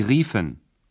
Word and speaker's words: riefen: The U in the infinitive changes to IE, pronounced E, in the imperfect tense. – Rufen. Sie riefen.